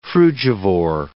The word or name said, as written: frugivore